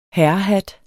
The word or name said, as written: herrehat